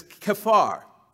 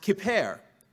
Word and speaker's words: kaphar: This word atonement in the Hebrew is kephar, K-A-P-H-A-R. It's also pronounced kipher, kipher or kephar, both pronunciations.